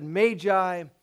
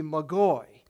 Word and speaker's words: Magi: I pronounce it Magi. Literally from the Greek language, it would be Magoi.